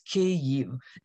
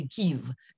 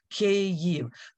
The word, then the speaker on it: Kyiv: It is two syllables, Kyiv. The first A sound is like in the word big. And the ending, sort of like yield, the same sound, except with a V at the end, Kyiv.